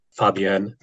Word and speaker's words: Fabien: The key here is, if it's F-A-B-I-E-N, do you pronounce the N at the end or not? – Do you nasalize the vowel Fabien?